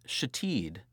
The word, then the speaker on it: Shithead: It was pronounced Shateed, but was in fact spelled Shithead.